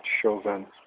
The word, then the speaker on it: Samhain: It's spelled Samhain, but it's pronounced Chauvin.